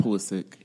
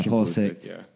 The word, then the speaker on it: Pulisic: We have, I can't say his name right, but it's like Poliznik, I think. Something like that. – Pulisic. – Yeah, Pulisic.